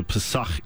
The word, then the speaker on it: pesach: So the in Hebrew you don't have the vowels, so you just have the pesach is that word. So it looks like a p and s, and the the ch is the sound at the end.